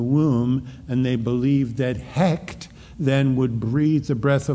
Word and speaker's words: Hekt: Her name was Hekt. H-E-K-T. Now, some spell it H-E-Q-E-T, Hekwet. Some spell it H-E-K-A, Heka. But the most often, and from the Egypt, is Hekt, H-E-K-T. She was a goddess again. And she is married to Khnum. You knew this, K-H-N-U-M, who is a creator god.